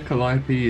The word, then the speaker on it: Calliope: You got it bang on there. Calliope is exactly how you pronounce it.